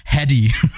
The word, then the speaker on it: Hedy: in Australian English, heady. – Yeah, yeah, you gotta really pronounce that H, bro.